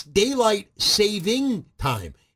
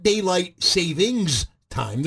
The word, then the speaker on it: daylight saving time: It's daylight saving time. It's not daylight savings time. There's no S at the, this isn't a bank account.